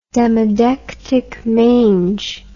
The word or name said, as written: demodectic mange